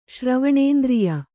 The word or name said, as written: ಶ್ರವಣೇಂದ್ರಿಯ